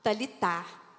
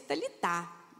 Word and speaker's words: Talita: que se chamava Talitar. Hoje, né, foi ficando mais moderno, coloca-se até TH, então nós pronunciamos, falamos Talita, mas aqui no português literal se pronuncia Talitar.